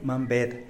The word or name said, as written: mambed